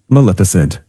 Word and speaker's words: Maleficent: Is it Maleficent? – Yes, Maleficent.